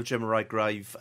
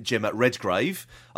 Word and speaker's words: Gemma Redgrave: Now, what's interesting, though, it will also see the return of Gemma Redgrave. I'll say that again, Gemma Redgrave. I don't know whose name I was pronouncing just then.